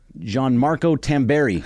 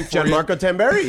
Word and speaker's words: Gianmarco Tamberi: Gianmarco Tamberi. I think I said that right. – That's the name. – Gianmarco Tamberi.